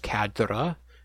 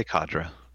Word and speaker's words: cadre: Because the, the instead of like you know factions and themes, we now have what the faction, the armies, and the cadre I, I actually just realized uh, I found out that it's a French word. It's actually pronounced cadre, so we're butchering it over mm. here. Just just say cadre.